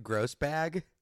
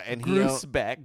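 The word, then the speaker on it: Grousbeck: Okay, your next person, Wick Grosbeck. I don't know if I'm saying his last name or his first name right. – It's Wick, I know that. – Wick Grosbag? – Grosbeck.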